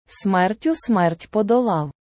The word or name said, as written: смертю смерть подолав